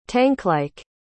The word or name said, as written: tanklike